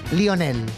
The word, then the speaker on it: Lionel: Es un gran dato porque como los argentinos lo pronuncian como se escribe más bien. – Lionel.